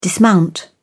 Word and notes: dismount: British English

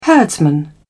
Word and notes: herdsman: British English

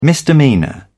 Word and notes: misdemeanour: British English

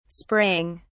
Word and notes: spraying: British English

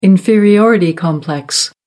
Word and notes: inferiority complex: American English

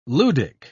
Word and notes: ludic: British English

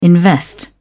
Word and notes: invest: British English

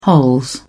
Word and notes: hols: British English

